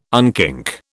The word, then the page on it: unkink: British English